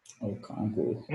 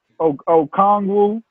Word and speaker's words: Okongwu: Okongwu. – Help me out with that. Okongwu.